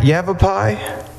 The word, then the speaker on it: Yavapai: It's the Yavapai. Is that, did I say that right? Yavapai? – Yes, sir.